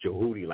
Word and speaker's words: Djewhti: It's like AMUN-RA, but the D is silent in that. So it's Djewhti, like Jedi.